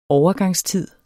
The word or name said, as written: overgangstid